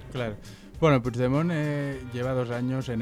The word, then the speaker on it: Puigdemont: Puigdemont, se pronuncia. – Puigdemont.